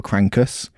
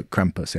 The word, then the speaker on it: Krampus: I can't pronounce it properly because whenever I'm really angry with my wifey, I say I'm becoming a right little crankus. And it's that, like, it's really hard. Like, you know, once you say a word so many times, you can't say it correctly. You say it wrong so many times. So Krampus. Yeah, it's great.